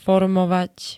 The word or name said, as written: formovať